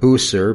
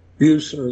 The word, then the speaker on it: Bucer: Not popularly. Bucer is the way we pronounce it in English.